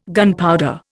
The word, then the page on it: gunpowder: Indian